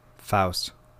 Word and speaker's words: Fuest: The Devil's Reign, directed by Robert F-U-E-S-T. How would you say that? – Faust. – Faust? All right. An American Satan, directed by Aram Garija.